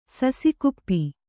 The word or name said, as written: ಸಸಿಕುಪ್ಪಿ